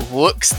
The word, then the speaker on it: wuxia: I'm going to pronounce this wrong, Wuxia series. – There you go. Wuxia, the deal with Wuxia.